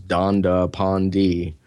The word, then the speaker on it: Dandapani: Um, if you Google Dandapani, and it's spelled exactly exactly as it sounds, Dandapani.